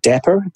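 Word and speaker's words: Dapr: So they're talking about this new open source project called Dapper, D-A-P-R. I think that's how you pronounce it, which is a portable event-driven runtime that takes some of the complexity out of building microservices and the open application model.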